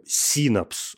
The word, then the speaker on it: синапс: А, давайте помнить, что в слове синапс, окей, ударение ставится на первый слог,